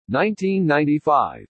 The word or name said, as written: 1995